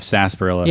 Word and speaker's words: sarsaparilla: S-A-R-S-A-P-A-R-I-L-L-A. – Yeah, it's like a that's like a Boston pronunciation of sarsaparilla. – Yeah, sarsaparilla. – Because you had that R on the end of an long A.